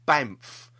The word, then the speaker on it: Banff: Doctor Who's won two awards at the 35th Banff. Now, not pronounced that wrong, Banff, BANFF, World Media Festival, which took place in Canada.